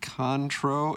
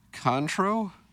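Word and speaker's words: Cointreau: Uh, Beercat says, I've got a real issue with how Jackson pronounces "cointro" in that Rosalita's ad. It's not Contro, it's Contro no emphasis at this point.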